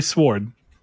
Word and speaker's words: Sword: He says you pronounce W. – You say the W silent. In Sword? – I swore I'd never do that again. – It's supposed to be silent.